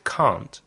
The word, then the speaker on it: can't: Can't has a long vowel sound, like the R in car.